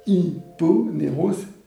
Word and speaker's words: Hyponéros: Alors, skate, ça s'écrit S-C-A-Y-T-H-E-S. Et d'Hyponéros, c'est D'apostrophe. Hyponéros, H-Y-P-O-N-E-R-O-S.